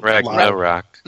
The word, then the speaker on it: Ragnarok: How do you pronounce that? That's the Thor thing, like the end of the world. – I've never said it out. – Ragnarok.